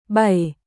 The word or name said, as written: bảy